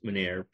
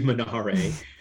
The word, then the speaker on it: Menare: And I remember Googling what I pronounce Menard, probably should be Menare, and the word started showing up on Italian language websites. And so I looked it up, and in Italian, M-E-N-A-R-E means to lead or guide someone on a journey.